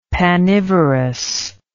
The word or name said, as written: panivorous